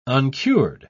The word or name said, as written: uncured